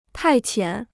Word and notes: 派遣: pài qiǎn

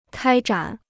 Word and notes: 开展: kāi zhǎn

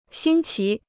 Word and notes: 新奇: xīn qí

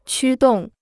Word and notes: 驱动: qū dòng

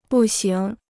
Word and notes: 步行: bù xíng

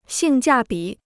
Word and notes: 性价比: xìng jià bǐ

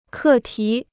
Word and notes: 课题: kè tí

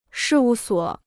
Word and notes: 事务所: shì wù suǒ

